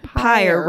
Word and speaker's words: pyre: Pyre rhymes with fire. – Ooh. But it starts with the P.